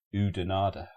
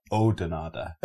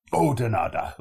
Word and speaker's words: Oudenaarde: Now, to an English speaking person, that just looks like Oudenaarde or Oudenaarde, right? And we were going, where's Oudenaarde? And the people we got told off because it's pronounced Oudenaarde. You don't pronounce the U, so it's like a Oudenaarde.